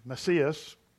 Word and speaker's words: Messias: All right, John chapter 4, beginning in verse 25, it says, "The woman said unto him, I know that the Messias," am I saying it right? – Yes.